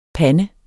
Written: pande

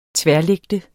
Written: tværlægte

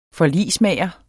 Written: forligsmager

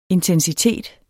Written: intensitet

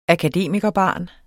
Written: akademikerbarn

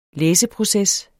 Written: læseproces

